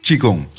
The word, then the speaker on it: Qigong: Eh, ik kan niet uitspreken. Kishong? – Qigong. – Qigong.